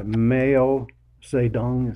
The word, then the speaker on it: Mao Zedong: Both Hitler and Stalin were outdone by Mao Zedong, is how you say it, by Mao, from 1958 to 1961 in what he called the Great Leap Forward.